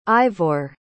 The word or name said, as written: Ivor